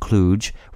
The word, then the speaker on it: kludge: The German word was spelled K-L-U-G. What we do know is that speakers of American English have agreed to keep the D silent, making the vowel pronunciation of kludge reflect the pronunciation of the German word klug.